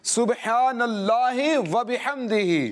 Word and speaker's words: سبحان اللہ وبحمدہ: ہاں, اس کے تلفظ بھی درست کرنا ہے. صبح با میں کل کلا آپ نے ضرور کرنا ہے, اور ہا کا تلفظ بھی آپ نے پیش نظر رکھنا ہے, سبحان اللہ وبحمدہ. جی ہاں, کوشش کریں اپنی زبان سے اللہ پاک کا ذکر کرتے رہیں اور اس کی برکتیں حاصل کرتے رہیں.